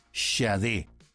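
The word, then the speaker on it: Sade: Créame que he visto y escuchado diversas pronunciaciones del nombre de esta artista y tuve que buscar puntualmente una en la cual ella aclaraba la forma de pronunciar su apellido, Shade.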